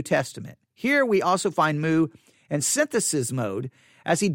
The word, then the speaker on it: Moo: The first book, A Theology of Paul and His Letters, The Gift of the New Realm in Christ, by Douglas, and his last name is spelled M-O-O. I'm going to say Douglas Moo.